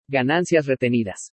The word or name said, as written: GANANCIAS RETENIDAS